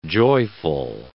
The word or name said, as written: joyful